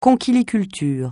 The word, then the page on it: conchyliculture: francais